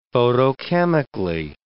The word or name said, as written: photochemically